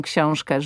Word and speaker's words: książkę: ale że powie książkę, książkę, że to ę zabrzmi nie jako książkę, bo to jest błąd. Tak. To jest hiperpoprawna wymowa. Książkę, rękę, ale że to ę wybrzmi. Rękę.